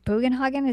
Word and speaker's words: Bugenhagen: Like, literally the only thing we get from that is Tifa going to like with Bugenhagen, is that how you say his name? – Yeah.